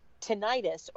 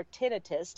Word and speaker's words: tinnitus: An audiology professor who co-authored a paper on COVID-19 and tinnitus, or tinnitus, depending on how you pronounce it, was published Monday, got quite a response.